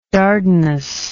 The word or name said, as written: dardanus